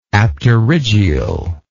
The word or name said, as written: apterygial